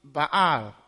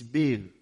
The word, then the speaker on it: Baal: That is the word spelled B-A-A-L. In Cameroon, we pronounced it Baal or Baal. And here it seems it is Baal. But when I went to Kereni to take a service there for Holy Communion, the reader read it Baal. And so I was comforted that even if I said Baal, I would not be completely wrong.